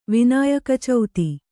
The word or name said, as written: ವಿನಾಯಕಚೌತಿ